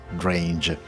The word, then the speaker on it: Drenge: Drenge in danese vuol dire boys, vuol dire ragazzi. Loro in realtà in Inghilterra lo pronunciano Drange.